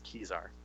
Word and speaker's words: Kezar: Yeah, it's actually pronounced Kezar, by the way, just so you know.